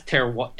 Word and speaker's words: terroir: It has depth, and what I mean by that is like you know this term ter- ter- terroir. I'm saying it wrong. Terroir. – Terroir.